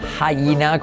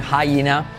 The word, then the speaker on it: hyena: Tenemos coyote, coyote, conmigo, coyote, cocodrilo, crocodile, crocodile, número tres, hyena, como gallina pero con G, hyena, con H, perdón, caimán alligator, ale, ale, ale, alligator, ah, y luego tortuga turtle.